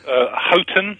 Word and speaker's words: Houghton: Houghton and Osijek.